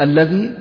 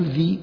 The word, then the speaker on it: الذي: الذي لا تقول ذي، ذي اسم إشارة صحيح، إنما تقول الذي،